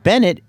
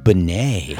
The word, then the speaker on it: Benet: His last name is pronounced Francais. It's not Bennett. It's LJ Benet.